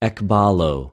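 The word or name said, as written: ἐκβαλλω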